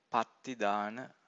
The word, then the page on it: pattidana: Pali